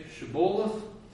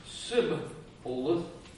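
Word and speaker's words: Shibboleth: They said, all right, say Shiboleth. If he said Sibboleth, because he could not pronounce the word correctly.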